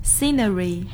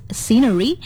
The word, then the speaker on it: scenery: Maybe like scenery. Is it right? – Oh no, it's scenery. – Scenery. So the right to pronounce is scenery.